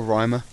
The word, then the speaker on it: Ryma: Yeah, it's it's spelled um, R Y M A, so it's either Rimmer or Rhymer. So I kind of alternate.